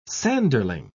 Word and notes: sanderling: British English

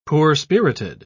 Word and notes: poor-spirited: British English

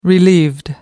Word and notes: relieved: American English

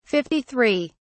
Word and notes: 53: British English

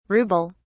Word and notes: rubel: British English

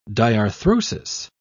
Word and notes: diarthrosis: British English

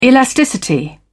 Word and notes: elasticity: British English

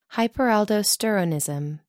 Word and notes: hyperaldosteronism: American English

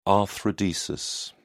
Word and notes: arthrodesis: British English

